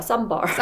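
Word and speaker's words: Ssäm Bar: 근데 이게 발음이 삼바예요 삼바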